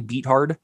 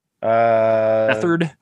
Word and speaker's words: Beathard: Is Beathard? Is it really Beathard? Bethard. – Bethard. There you go.